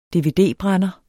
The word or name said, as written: dvd-brænder